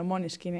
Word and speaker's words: Måneskin: Bueno, Moneskin, ¿eh? cuidado. – Bueno. ¿eh? Uh, que es la buena pronunciación en danés.